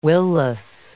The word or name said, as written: will-less